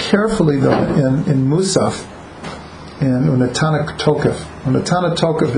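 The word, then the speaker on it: Unatana Tokef (ונתנה תוקף): Unatana Tokef.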